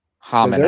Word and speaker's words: Homan: Homan, I think is how it's pronounced.